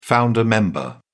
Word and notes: founder member: British English

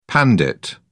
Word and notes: pandit: British English

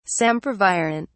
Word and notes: sempervirent: British English

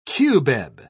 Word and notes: cubeb: British English

